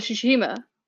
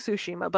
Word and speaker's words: Tsushima: It's Tsushima, but I always say Tsushima. It's Tsushima. Nope, it is Tsushima.